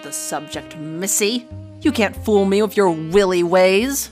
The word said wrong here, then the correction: wily: It's pronounced wily. While, but an adjective.